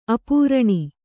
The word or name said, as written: ಅಪರ್ಣ